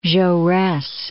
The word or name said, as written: Jaures